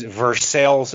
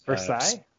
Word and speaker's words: Versailles: It was Versailles, Ohio. Versailles. – Versailles? – It was spelled Versailles, but it was pronounced Versailles, which I messed up because I was like, hey, is this Versailles?